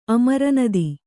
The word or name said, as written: ಅಮರನದಿ